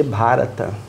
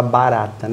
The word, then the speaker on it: Bharat: Bharata, que muita gente fala Bharata, né? Mas é B-H-B-H-A e é a pronúncia diferente de Bharata. Então, provavelmente muita gente vai chamar de Bharata, mas a pronúncia é Bharat.